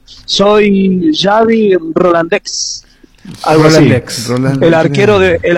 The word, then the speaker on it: Xavi: Claro, Xavi que sería como Javi.